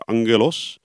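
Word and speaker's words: angelos (ἄγγελος): In het Grieks spreek je het wel een beetje anders uit. Dan zeg je angelos. Angelos, angelos, engel, engel.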